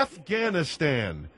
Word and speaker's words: Afghanistan: That's the people that live in the Ghanistan portions. – Yeah, Afghanistan, not Ghanistanis.